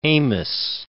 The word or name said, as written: Amis